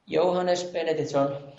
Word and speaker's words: Jóhannes Benediktsson: Johannes Benediktsson.